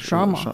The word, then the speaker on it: chama: Oder Chama, ich weiß auch nicht, wie die Chama, Portugiesen es aussprechen. Nein, mit Chama. Chama.